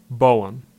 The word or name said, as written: Bowen